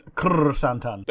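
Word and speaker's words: Krrsantan: What, Black Kasantan? – Yeah, that's the one, because it's not spelt like that. – Yeah, well, it's Krr-Santan.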